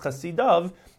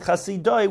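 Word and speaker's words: chasidav (חסידיו): Although it's pronounced chasidav, it's written chasidoi,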